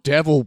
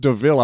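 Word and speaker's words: De Vil: Like the whole the bit where Horace at the end is just the fact that twice he has to point out, like, it's spelt devil, but it's pronounced DeVille.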